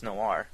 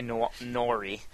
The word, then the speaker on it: noir: No, it's well, that's sweet, but it's exposed to me how many people in my life have no idea how to pronounce noir. – Oh, why? – I've been hearing quite a few people say Nori. L.A. Nori.